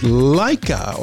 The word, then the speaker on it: Laika: The dog's name, I'm probably going to say it wrong, but it was Laika or Laika, L-A-I-K-A, Laika, Laika, anyways.